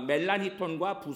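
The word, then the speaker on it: Melanchthon: 필립 멜랑크톤, 한국 발음으로는 멜란히톤이라고 하는데요. 마르틴 부서, 그리고 요한 피스토리어스 이 세 명이 개신교를 대표하는 세 명의 신학자였습니다. 특히 멜란히톤과 부서는 마틴 루터와 요한 칼빈의 전기를 읽으면 거기서도 등장할 만큼 그 대표 종교개혁자들과 같이 사역을 많이 했던 인물들이었습니다.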